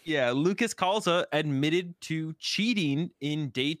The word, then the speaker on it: Calza: Calza. – How do you pronounce the last name? Calza.